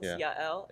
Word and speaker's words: Yael: So it's actually, I think, once you actually look at it and hear the two syllables, Yael, it's not easy.